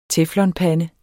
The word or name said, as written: teflonpande